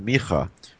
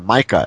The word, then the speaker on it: Micah: Now, one of the signs, one of the clues that this passage here in Micha, or Micah as people might pronounce it,